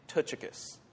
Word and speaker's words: Tychicus: I'm going to tell you the actual pronunciation of his name is Tychicus. Okay? That's how you actually say it.